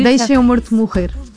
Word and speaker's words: Deixa-me Morto Morrer: It's in Portuguese, it's very hard to pronounce Portuguese, but it's almost Portuguese. – No, you Deixa-me Morto Morrer.